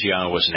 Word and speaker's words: Yahweh: They are always pronounced as I am sharing them with you, Yahweh.